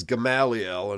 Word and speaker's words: Gamaliel: So anyway, they have it here as Gamaliel, and that's the usual way it's pronounced.